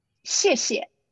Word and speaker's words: 谢谢: 谢谢. – 谢谢.